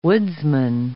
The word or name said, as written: woodsman